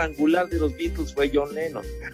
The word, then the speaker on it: Lennon: Lo importante es el legado que dejó Juan Lennon no, John Lennon. Lennon, así, pronúncialo. Lennon.